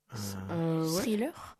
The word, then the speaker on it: thriller: Thriller. Attends, vous prononcez ça comment? – Thriller. – Ouais, thriller. – Ouais, ouais, tu l'as bien prononcé.